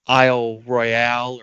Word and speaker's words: Isle Royale: Um Isle Royale or Isle Royal, you know, depending on how much of a redneck you are, is which which way you pronounce that.